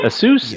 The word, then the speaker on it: Asus: I was thinking as a laptop, I was going to get, I don't know if I pronounce that. Asus? A-S-U-S? – Mm-hmm. Asus. – That's how you pronounce it? – That's how we do it.